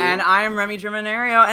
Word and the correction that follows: Germinario: And I said my name wrong. It's Ario. And yeah. I said Aria. That's so weird. Hey, I'm Remy Germanario.